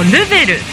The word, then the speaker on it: REVEL: はい、こちらお客様一人一人に合わせた施術をしてくださる美容院、そしてこうルベルという意味なんですけど、 REVEL と書いてルベル、